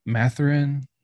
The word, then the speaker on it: Mathurin: So then I got Jaden Ivey, Benedict Mathurin.